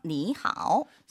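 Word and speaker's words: 니 하오 (你好): 저 니 하오. – 삼성 삼성일 때는 앞에 있는 삼성이 이성으로 발음된다는 거.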